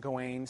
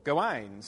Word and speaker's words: Gawain: Many people pronounce his name Gawain. Some people pronounce it Gawain. I do both because that's more fun. And frankly, I think there's justification for that. Gawain, from the rhyming-based poems, especially those which are derived from the French, the emphasis tends to be on the last syllable, Gawain, so that you can rhyme it with other things.